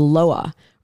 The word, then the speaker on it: lwa: It's done to combine the life force with something called the loa, or that it, it's spelled LWA. So I'm not sure if there's two different pronunciations, but I know Loa is sure.